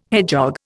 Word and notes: hedgehog: Indian

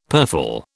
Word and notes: purfle: British English

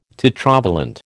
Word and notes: tetravalent: American English

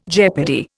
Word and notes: jeopardy: Indian

